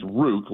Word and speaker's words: Ruke: It's Ruke. It's Ruke, like Juke.